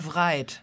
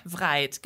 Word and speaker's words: Wright: Also es schreibt sich mit W vorne. Wright. Wright, Gebrüder Wright.